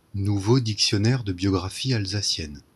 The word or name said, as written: Mathieu Kappler